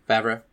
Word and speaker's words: Favreau: Favreau.